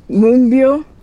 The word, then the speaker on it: Moonbyul: Is it like there's a Y in there that's throwing him off? But Moonbill, is that close? – Like Moonbill.